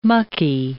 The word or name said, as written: mucky